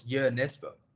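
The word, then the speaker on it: Jo Nesbø: And I told that the author's name is Joe Nesbo. And then he goes, oh, it's Jo Nesbo. And I'm like, okay, so I've been pronouncing it completely wrong.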